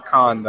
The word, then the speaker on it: Cannes: Some people say Cannes. Some people say Con. It just depends on who you are, bro. I'm pretty sure it's Con though.